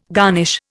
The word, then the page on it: garnish: Indian